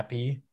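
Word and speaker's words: Zappe: Zappy.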